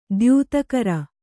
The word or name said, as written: ದ್ಯುತಿಗರೆ